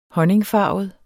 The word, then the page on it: honningfarvet: [ -ˌfɑˀvəð ]; [ -ˌfɑːvəð ]